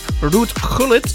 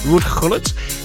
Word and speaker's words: Ruud Gullit: Ex-football player Ruud Gullit, should I say that right? Yeah, it's Dutch. Ruud Gullit is 64 today.